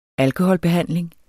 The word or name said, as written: alkoholbehandling